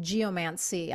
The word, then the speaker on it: geomancy: and geomancy. I'm not sure if I pronounced that correct. – That's correct.